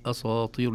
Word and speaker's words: أساطير: تبعا للطاء فنقول أساطير نقول أساطير وذلك نقول للطلاب دائما إذا أرادوا أن يتدربوا عليها يقسموها كلمة قالوا أسا ويستطيع أن يقول أسا لوحدها ثم طير أساطير